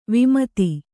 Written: ವಿಮದ

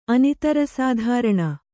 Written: ಅನಿತರಸಾಧಾರಣ

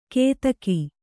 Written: ಕೇದಗಿ

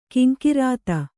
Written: ಘೀಂಕೃತಿ

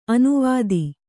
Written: ಅನುವಾದ

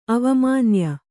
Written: ಅವಮಾನ್ಯೆ

